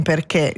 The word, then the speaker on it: perché: L'accento acuto indica invece una vocale chiusa, E come in perché.